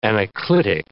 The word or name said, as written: anaclitic